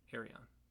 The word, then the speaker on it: Earien: E-A-R-I-E-N. Arion.